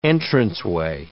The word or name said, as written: entranceway